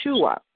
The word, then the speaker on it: Yeshua: By his apostles and Mary and Joseph, they did not call him the pronunciation Jesus. They called him Yeshua.